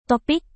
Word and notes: topik: Indonesian

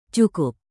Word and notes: cukup: indonesian

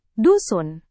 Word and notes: dusun: indonesian

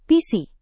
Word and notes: visi: Indonesian

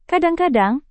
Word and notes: kadang-kadang: Indonesian

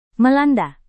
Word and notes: melanda: Indonesian

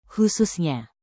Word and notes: khususnya: Indonesian

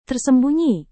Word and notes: tersembunyi: indonesian

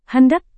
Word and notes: hendak: Indonesian

